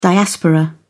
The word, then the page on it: diaspora: British English